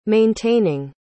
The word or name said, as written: maintaining